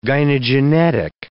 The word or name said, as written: gynogenetic